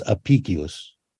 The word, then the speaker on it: Apicius: Yeah, in classical Latin, what is spelt with a C was always pronounced like a K. So in classical Latin, it was Apicius.